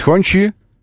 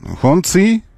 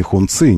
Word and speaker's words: Хончи: что есть Хончи или Хонци, как их называют где-то, но мы называем Хончи, потому что здесь и китайцы настаивают сами из представительства, что так по-русски произносится. И юридическое обозначение компании, просто кириллическое написание, не Хунцы, никак, не Хонцы, а Хончи, вот так.